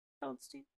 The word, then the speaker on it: Feldstein: Feldstein.